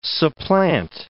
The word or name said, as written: supplant